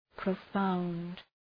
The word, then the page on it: profound: prə’faʋnd